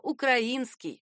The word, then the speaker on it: украинский: Напишем шрифтом исполинским, что верно только украинский. Ударение «украинский» входит в так называемый расстрельный грамматический список, составленный ресурсом «Лукоморье». По словам его авторов, говорить «украинский», «украинец», «украинка» с ударением на «а» можно только тем, кто свалился как минимум из XIX века и использует вариант стихов Пушкина и перенес войну в Украину.